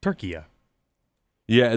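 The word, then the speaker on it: Türkiye: Turkiye, I believe. – Turkey. – Yeah, they pronounce it Turkey Ye.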